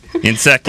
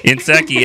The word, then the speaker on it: Nsekhe: Nsekhe. – Ty Niseki. Nsekhe. – It's actually once you once you hear it and see it, it's like, oh, that kind of makes sense. It's Ty Nsekhe.